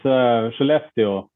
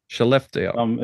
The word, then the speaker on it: Skellefteå: Yeah, it's Skellefteå. – Skellefteå. – It's like she left you.